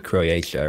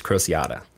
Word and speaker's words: Crociata: I was saying Croatia or something like that, but it's Crociata.